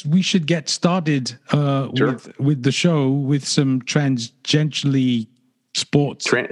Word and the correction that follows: tangentially: Well, there's no S. It's tangentially.